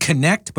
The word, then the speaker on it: Quanet: It looks like the word connect, but it's spelled with a Q. So I guess it's not a qua, it's a k. So it's Connect, but with a Q.